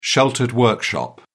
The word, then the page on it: sheltered workshop: British English